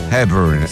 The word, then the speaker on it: Hepburn: Hepburn.